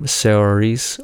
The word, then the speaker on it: Saoirse: Saoirse Ronan in Lady Bird.